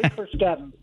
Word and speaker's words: Carskadon: It's Trey Carskadon.